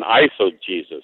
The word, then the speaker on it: eisegesis: So it's exegesis and eisegesis, right? – Yeah, is spelled E-I-S-E-G-E-S-I-S. Eisegesis. Actually, it would probably be pronounced eisegesis in Greek, but we would say ice probably.